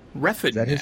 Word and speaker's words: Refn: Nicholas Winding Refn? – Refn, actually. Refn. I had to look that up because I thought it was Rain, but it is Refn. At least that's how Gosling pronounces it, and Baby Goose would know since they apparently like roomed together while they were filming stuff.